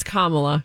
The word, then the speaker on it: Kamala: It's Kamala. – Is it? Because did we land on Kamala? – Yeah, I heard it was Wayzata.